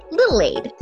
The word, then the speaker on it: Lilaide: So tonight I am playing Little Aid. That's LILAIDE. Just because, yeah, some people don't understand when I say her name.